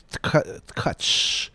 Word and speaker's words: Tkatsch: We'll next hear Israel Katch, T-K-A-T-S-C-H. They say it's pronounced Katch.